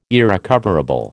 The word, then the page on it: irrecoverable: American English